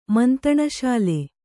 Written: ಮಂತಣಶಾಲೆ

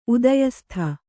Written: ಉದಯಸ್ಥ

